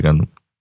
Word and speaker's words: Gundam: You pronounce it Gundam in English? – Gundam. – Okay.